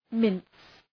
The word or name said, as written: mince